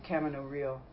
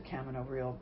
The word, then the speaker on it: Camino Real: We said Camino Real down south, but it's Camino Real, isn't it, I think? I don't know. – In Spanish it is, but okay. I think the way Tennessee Williams pronounced it, it was supposed to be Camino Real. – Okay. – Because the people weren't supposed to be able to pronounce it correctly.